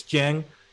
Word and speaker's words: Dieng: Apparently we mispronounced it in our last video. – Did we? – He signed with the Spurs. Yeah, supposedly. Whatever. People are on our grills whenever we mispronounce. I think so. I think it's Dieng. I'm committing to that one.